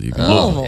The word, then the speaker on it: Louisville: Louisville. Yeah. It's Louisville. – It's like shovel, only with an L.